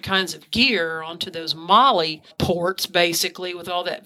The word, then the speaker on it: MOLLE: Now, what he's referring to is a Molly bag. It's spelled M-O-L-L-E.